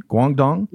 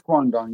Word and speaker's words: Guangdong: Now, this mainly impacted the ports across the Chinese province of Guangdong. If I said that right, Guangdong. Yeah, it's Guangdong.